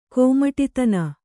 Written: ಕೋಮಟಿತನ

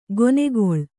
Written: ಕೆನೆಗೊಳ್

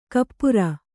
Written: ಕುಪರಿ

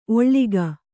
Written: ಒಳ್ಗು

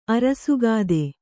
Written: ಅರಸುಗಾದೆ

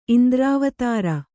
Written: ಇಂದ್ರಾವತಾರ

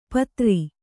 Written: ಪಾದರಿ

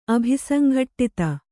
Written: ಅಭಿಸಂಘಟ್ಟಿತ

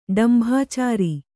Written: ಡಂಭಾಚಾರ

